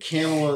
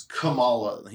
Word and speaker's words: Kamala: And I think Kamala is the wrong. It's Kamala. He put the wrong emphasis on the wrong syllable.